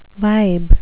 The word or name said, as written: vibe